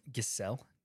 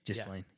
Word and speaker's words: Ghislaine: Is it Ghislaine? – Ghislaine, yeah. Noelle Marion Maxwell. – At least that's how they've said it on, like, different videos I've watched or something. – Yeah, yeah. Well. Ghislaine. – It's spelled with a G, right? – Mm-hmm.